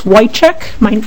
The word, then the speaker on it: Wojchik: My name is Phyllis Wojcik, and it's spelled W-O-J-C-H-I-K.